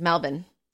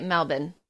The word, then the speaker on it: Melbourne: Well, and the way that they pronounce it is Melbourne. Yeah. – That to me, because I said Melbourne, and Eric was like, it's Melbourne, and then he's also wrong. So Melbourne. Take that, Eric, but he was wrong. – Yeah, but yeah, they do, they say it Melbourne.